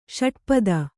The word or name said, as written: ಷಟ್ಪದ